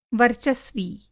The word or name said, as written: वर्चस्वी